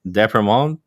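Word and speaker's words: d'Aspremont: We have Alex Depremont.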